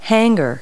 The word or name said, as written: hanger